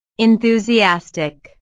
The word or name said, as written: enthusiastic